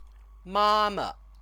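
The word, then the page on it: 妈妈: māma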